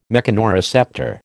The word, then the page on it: mechanoreceptor: American English